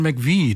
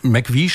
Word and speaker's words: McVie: Und McVie schreibt sich MC, aber das spricht man ja so nicht aus, sondern deswegen wurde es einfach Mac, M-A-C geschrieben.